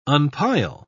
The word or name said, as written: unpile